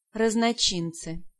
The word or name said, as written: Raznochints